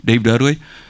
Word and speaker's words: Dave Dudley: Dave Dudley, si on veut le bien prononcer avec un accent du sud, bien country. Dave Dudley.